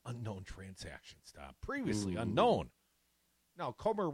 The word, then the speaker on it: Comer: Comer, rhymes with Gomer,